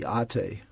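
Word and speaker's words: ate (ἄτη): They have this word ate, A-T long E, ate, which is the goddess of blindness and causes people to act blindly.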